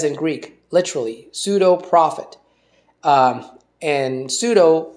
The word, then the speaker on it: pseudo: And so the word false prophet, or the phrase false prophet, was translated from the word pseudo prophetess, pseudo, P-S-E-U-D-O, which I pronounce pseudo, but I think, you know, we would pronounce it pseudo in English.